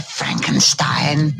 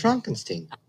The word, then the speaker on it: Frankenstein: Dr. Frankenstein. – It's Frankenstein. – You're putting me on. – No, it's pronounced Frankenstein.